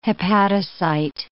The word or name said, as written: hepatocyte